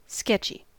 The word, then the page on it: sketchy: American English